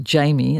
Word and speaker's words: Jaime: Going to go to Los Angeles now, take a little road trip to LA, and Zahara, I think this is pronounced Jamie, it's J-A-I-M-E, although this person is of Mexican and Filipino heritage, so perhaps it might be Jaime.